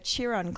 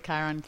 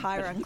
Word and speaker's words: Chiron: Today we're here from the Chiron Clinic. Did I say that right? – It's actually the Chiron Clinic. – Chiron Clinic.